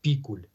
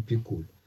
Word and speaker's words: Пикуль: А ударение все-таки стоит на первом слоге – Пикуль, а не Пикуль.